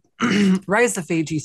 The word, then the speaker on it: rhizophagy: What is the rhizophagy cycle? Did I pronounce that right? – Oh, the rhizophagy cycle. – Yes. – Rhizophagy. Yeah. So that's pretty much, it breaks down to roots and eating, the roots eating cycle.